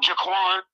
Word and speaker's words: Jaquan: Oh, how do you say his name? Is it Jaquan or Waquan? Jaquan Brisker, who came over from the Bears, which Mike Horn ain't like it.